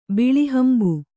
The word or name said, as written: ಬಿಳಿಹಂಬು